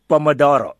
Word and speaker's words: Pomodoro: Pomodoro.